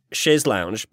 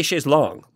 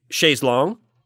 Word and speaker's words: chaise longue: L-O-U-N-G-E, like lounge. – Mm-hmm. – But this is L-O-N-G-U-E, and they do say chaise longue. Like it's it's long, not lounge. But you're telling me that the French pronunciation of this would be chaise longue? – Chaise longue, yeah. – And it would be spelled like L-O-N-G-U-E.